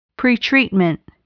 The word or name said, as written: pretreatment